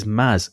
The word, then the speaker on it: Mads: And I think the correct way of pronouncing M-A-D-S in Danish is Maz.